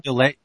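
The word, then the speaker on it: Dillies: Delay. D-I-L-L-I-E-S. R-E-N-A-U-D. – Wait, what is it again? – First name, R-E-N-A-U-D, Renault.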